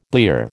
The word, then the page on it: blear: American English